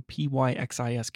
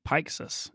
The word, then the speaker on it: Pyxis: But I mean, when I see it, I pronounce it Pyxis. – Pyxis, that, yeah, okay. – Because that, you know, Pix camera, you know, that kind of.